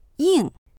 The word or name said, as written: ying4